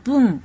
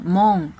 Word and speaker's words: 聞: 音读的时候读作 boon 或者 mon，比如读作 boon 的发音的单词有报纸。